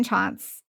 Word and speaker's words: Tschanz: My name, Megan Chance.